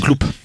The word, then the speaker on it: Cloup: j'aurais pu passer euh, le dernier album de Michel Clou en fait, euh, mais Cloup, on dit Cloup, Cloup. – Ouais. Non comme dans le euh, sud de la France, on dit Cloup. Ça se prononce Cloup.